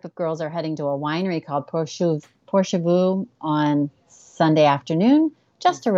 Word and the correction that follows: Porchview: Sorry, and I butchered the name Porchview on Sunday afternoon.